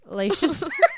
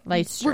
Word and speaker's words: Leicester: And she's from, how do you say that? – Leicester. Shit. Oh, no. I knew it. – Okay. – Leicester.